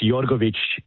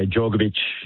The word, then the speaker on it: Jorgovic: Well, it's pronounced Jorgovic in my parents' language, but yeah, Jorgovic, whatever it is, that's fine.